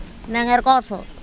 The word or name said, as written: mengerkOtHo